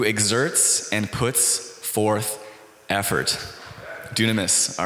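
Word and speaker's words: dunamis (δύναμις): And the Greek word for actually ability that's used in that verse 15 is pronounced dunamis.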